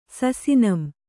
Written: ಸಸಿನಂ

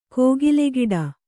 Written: ಕೋಗಿಲೆಗಿಡ

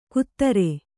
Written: ಕೂತರೆ

